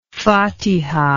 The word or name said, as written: fatiha